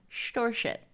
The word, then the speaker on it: Storset: I had to look up the official Norwegian pronunciation. You're welcome. Storset.